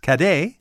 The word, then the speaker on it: cadet: And in French, the word cadet is pronounced cadet. – Uh-huh. – And the Scots changed it to caddy.